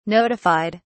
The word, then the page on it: notified: British English